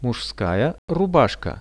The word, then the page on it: muzhskaja rubashka: Russian